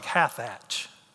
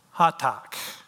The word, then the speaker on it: Hathach: Then Esther called out for, and you see this word here, and it looks like Hathach, right? It's actually Hatak, if you want to pronounce it correctly.